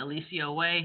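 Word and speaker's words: Eliseo Way: It's spelled ELISEOWAY, Alicia Way.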